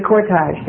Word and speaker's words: cortege: And he said he remembered watching the funeral cortages, what do you say, going to. Mr. Pronounce that word. The cortege, thank you.